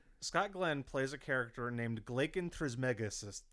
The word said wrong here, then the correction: Trismegistus: No, hold on. Keep that flub. This is important. Scott Glenn plays a character named Glaken Trismegistus.